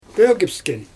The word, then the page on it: pögpsken: Xyzyl